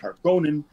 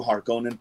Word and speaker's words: Harkonnen: Eh, es con las primeras grandes casas y aparecen los Harkonnen. yo les digo Harkonnen porque me quedé con la la, pronunciación de David Lynch, Harkonnen me suena rarísimo, pero bueno.